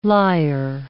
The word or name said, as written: lier